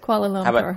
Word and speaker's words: Kuala Lumpur: Koala. – Okay. – Koala is an animal in Australia. – What is it? How do you say it? – Kuala Lumpur.